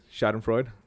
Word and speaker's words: Schadenfreude: I'm going to bring it back to the new record, Shot and Fraud. I'm sure I'm saying that wrong, but every time. – It's Freud. – Shot and Freud. Schadenfreude, I'm terrible at that.